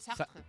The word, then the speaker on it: Sartre: Yo solo os voy a decir una cosa, que no es que la diga yo, que la decía Jean-Paul Sartre. ¿Lo he dicho bien? – Uy, fenomenal. Sartre.